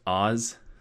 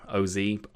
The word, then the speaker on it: AHS: But in parentheses, they say it's pronounced Oz, so O-Z, Oz.